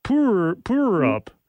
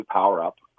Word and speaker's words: Power Up: Is it Power Up or is it Poor Up? – That's the stylized version. It is actually Power Up.